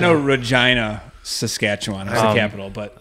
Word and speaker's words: Regina: The capital of Saskatchewan, it looks like Regina is the name of it. It's pronounced Regina.